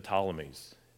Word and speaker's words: Ptolemies: And these two dynasties come in the forms of two of them here, named the Seleucids and the Ptolemies. It's a silent P. So the Seleucids and the Ptolemies in verses 5 through 7.